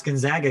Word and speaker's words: Gonzaga: Gonzaga, which I know is pronounced Gonzaga because I heard somebody say that way on television. It blew my mind. I was like, that's how you say that? It sounds wrong.